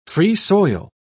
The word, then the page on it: free-soil: British English